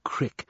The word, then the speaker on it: creek: He reached a large stream, of the kind the locals called a creek and pronounced a crick, and decided to follow it.